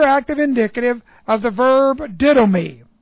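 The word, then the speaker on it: Didomi (δίδωμι): Didomi. D-I-D long O-M-I.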